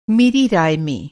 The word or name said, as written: राय